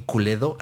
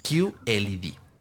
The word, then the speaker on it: QLED: Que aquí decimos QLED. O que se ve bien culedo. Ah, pero no, se pronuncia QLED.